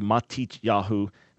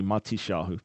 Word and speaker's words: Matityahu: It's his real name, his given name, but Matisyahu, of course, is Hebrew for Matthew, Matityahu, modern Israeli pronunciation, colloquially Matisyahu.